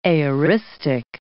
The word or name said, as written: aoristic